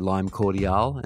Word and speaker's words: Cordiale: I always called them Lime Cordial. My girlfriend corrected me recently and said that it's actually Lime Cordiale and it is spelled Lime Cordiale.